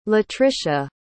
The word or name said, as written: latrisha